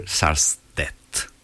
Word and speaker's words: Sarstedt: Where do you go to, my lovely, di Peter Sarstedt. Molto difficile da pronunciare. S-A-R-S-T-E-D-T. Sarstedt.